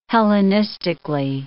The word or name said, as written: Hellenistically